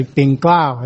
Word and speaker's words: Bing Klao: I know we send in the prayer request and it looks like Pink Leo or something like that, but it's actually Bing Klao is how you pronounce it.